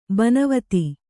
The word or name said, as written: ಬೋನವೆತ್ತು